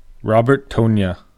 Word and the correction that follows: Robert Tonyan: The correct pronunciation is Robert Tonyan.